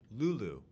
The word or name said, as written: LULU